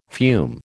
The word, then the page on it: fume: American English